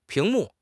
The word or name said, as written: 屏幕